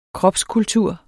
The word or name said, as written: kropskultur